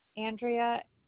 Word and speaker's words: Andrea: Andrea, A-N-D-R-E-A. Last name is Mortellaro, M-O-R. T-E-L-L-A-R-O.